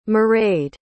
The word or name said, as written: mairead